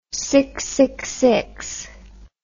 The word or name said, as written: six shooter